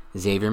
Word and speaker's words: Xavier: Xavier McKinney, the X is not pronounced.